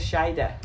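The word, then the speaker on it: Shaeeda: Except they don't say that. She says Shaida. – Shaida. – Shaida. – Yeah, it's S-H-A-E-E-D-A. – I know how it's spelled, but she says Shaida. – I know. Yeah, there's one too many vowels.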